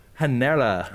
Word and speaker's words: Hanera: Hanera. – Oh, I said Genera. – Yeah. – So it's an H. – It's an H sound.